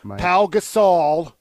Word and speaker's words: Gasol: Gasol. Pau. How do you say his last name? – Oh, Gasol. – My God. – Pau Gasol. – Pau Gasol. – Good job.